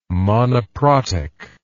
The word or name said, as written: monoprotic